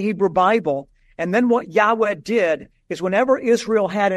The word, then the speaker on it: Yahweh: Most Semitic scholars believe that's pronounced a breathy Yahweh.